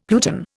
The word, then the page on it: pluton: Indian